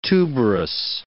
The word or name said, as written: tuberous